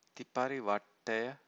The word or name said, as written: tiparivattaya